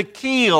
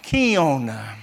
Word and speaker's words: kiln: And of course, we're talking tonight about going through the fire or being placed in the keel, or sometimes pronounced kiln.